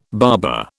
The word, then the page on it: barber: British English